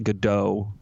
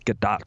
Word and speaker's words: Gadot: Somebody on the internet said that it's not Godot, like French. It's Godot because she's Israeli.